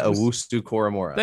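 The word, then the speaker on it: Owusu-Koramoah: I can never pronounce Jeremiah Owusu-Koramora.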